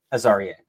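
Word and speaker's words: Azareye'h: I finally figured out how to say that correctly. Thank you, Clay Fink, who DM'd me the pronunciation on Twitter. – Azariah.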